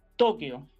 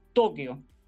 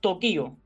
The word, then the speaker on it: Tokio: En el uso actual, escuche bien, la pronunciación adecuada es Tokio, así que la capital de Japón es Tokio. Por lo tanto, se desaconseja totalmente una pronunciación antigua y poco usada que era Tokio.